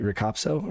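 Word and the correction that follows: RikaPSO: or Rika? – Rika PSO. – Rika PSO. Okay.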